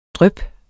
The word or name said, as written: dryp